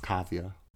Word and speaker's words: Kaavia: I'm happy that she has her daughter, Kavya. – That's her name? – Yes, Kavya. – How you spell that? – K-A-A-V-I-A.